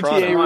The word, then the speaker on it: Toronto: Notice the no T pronunciation. – Exactly. I was about to say, the second T doesn't exist if you're from here. – Nope. – Yeah. It's GTA Toronto.